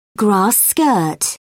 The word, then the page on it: grass skirt: British English